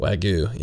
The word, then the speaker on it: Wagyu: Wagyu, yeah.